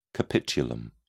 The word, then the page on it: capitulum: British English